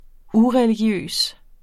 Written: ureligiøs

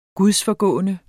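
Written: gudsforgående

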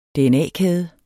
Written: dna-kæde

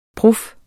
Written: prof

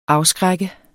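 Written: AFSKRÆK

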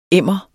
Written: emmer

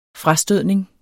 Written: frastødning

